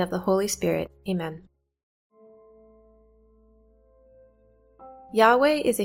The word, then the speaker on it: Yahweh: The name of God, I Am, was written with these four consonants, YHVH, that the Jews pronounced like this, Yahweh. The pronunciation Yahweh is undoubtedly the most correct pronunciation of the original Hebrew to indicate God as I Am Who I Am.